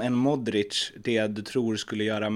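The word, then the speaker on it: Modrić: Modric. Modric.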